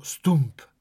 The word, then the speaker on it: stoemp: Le Stump, S-T-O-E-M-P. Une première difficulté qui peut se présenter pour un touriste qui lit un menu de restaurant sera la prononciation du mot Stump, qui, bien que s'écrivant Stoemp, se prononcera bien Stump.